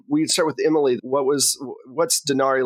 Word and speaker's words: Dresner: Since you're talking about spirits, let's talk about Dresner, right? Is that the right way to say it? Dresner? – Oh, yeah.